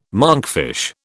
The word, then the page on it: monkfish: British English